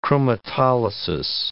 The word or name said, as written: chromatolysis